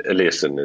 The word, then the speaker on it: Eliasson: Is that the correct pronunciation? – Icelandic, German, English. I mean, there's slight different. Eliasson is the kind of the one that I use.